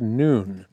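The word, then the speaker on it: noon (نون): It is pronounced noon.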